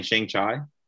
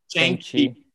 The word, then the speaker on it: Shang-Chi: How do you pronounce it again? Shang Chai. Shang Chi. Shang-Chi.